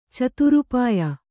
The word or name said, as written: ಚತುರೋಪಾಯ